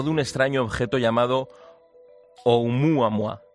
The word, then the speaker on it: Oumuamua: Muy buenas noches, pues muy bien, muy, muy bien pronunciado, en perfecto hawaiano. – Oumuamua.